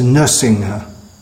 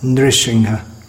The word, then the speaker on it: Nrisimha: Just came to mind one common mispronunciation in our daily ISKCON songs is pronouncing Nrisimha as Nursingha. It's not Nursingha, Nrisimha.